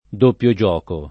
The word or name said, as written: doppio gioco